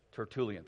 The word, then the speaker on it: Tertullian: I was like, Tertullian, thank you.